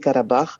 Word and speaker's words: Karabakh: Mais sinon, donc, vous pouvez prononcer Karabakh plus tard avec un R un petit peu roté.